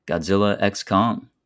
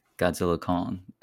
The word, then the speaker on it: Godzilla x Kong: Now, absolutely, moving into the box office breakdown for March 28th to the 31st, Godzilla X Kong, which apparently is supposed to be just called Godzilla Kong. X is silent.